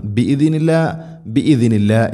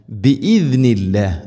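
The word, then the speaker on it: ബിഇദ്നില്ല (بإذن الله): ഐൻ ഇവിടെ ഒന്നും അലാ ഖൽബിക് ഇങ്ങനെ ഓതരുത്, അലാ ഖൽബിക് ഖാഫ് ഖൽബിക ബിഇദ്നില്ലാഹി ബിഇദ്നി ബിഇദ്നി ഈ പദം പ്രത്യേകം ശ്രദ്ധിക്കണം. ബിഇദ്നില്ല എന്നല്ല ബിഇദ്നില്ല. ആയത്തുൽ കുർസിയിലൊക്കെ പ്രത്യേകം ശ്രദ്ധിക്കണം.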